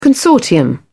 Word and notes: consortium: British English

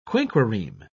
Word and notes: quinquereme: British English

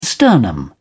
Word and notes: sternum: British English

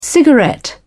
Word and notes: cigarette: British English

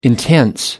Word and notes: intense: American English

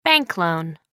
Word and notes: bank loan: American English

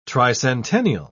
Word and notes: tricentennial: British English

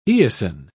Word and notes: eosin: British English

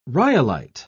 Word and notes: rhyolite: British English

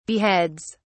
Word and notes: beheads: British English

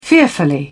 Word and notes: fearfully: British English

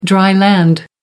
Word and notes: dry land: American English

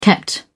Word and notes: kept: British English

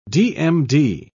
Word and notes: dmd: British English

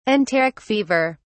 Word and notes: enteric fever: British English

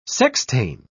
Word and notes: sextain: British English

